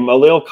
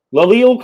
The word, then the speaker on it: Lael: Jaleel Collins, a former Bengal, former Cowboy. Laleel Collins? I'm sorry, either way. – L-A-E-L.